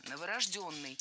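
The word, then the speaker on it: новорождённый: Новорожденный неверно, правильно? Новорожденный. Ударение ставим на «ё» в четвертом слоге.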